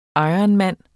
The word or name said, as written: ironman